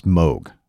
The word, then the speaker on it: Moog: Today on Rockin' the Suburbs, we hear about the importance of the Moog synthesizer in Ricky Peterson's musical development. – Patrick, that's actually pronounced Moog.